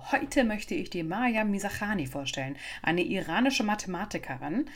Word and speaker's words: Maryam: Zumindest wurde sie so bei den Memorials von der Stanford University ausgesprochen. Die westliche Welt in den Reportagen und News spricht sie allerdings Miriam oder Miriam Mirzakhani aus. Ich entscheide mich jetzt für iranische Aussprache und hoffe, das ist in Ordnung.